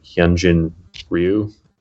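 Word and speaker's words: Hyun-jin Ryu: But yeah, again, like these guys you mentioned, they're all kind of jumping around, like Hyunjin Ryu, if I'm saying that correctly. – Yep, you got it.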